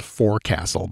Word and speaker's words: forecastle: Spelled forecastle, by the way, but it's pronounced forecastle.